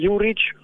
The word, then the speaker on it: Juric: Juric, así se pronuncia por lo menos allá,